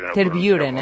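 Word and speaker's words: Tervuren: Terburen, hem de dir? – Terburen, és la pronunciació correcta.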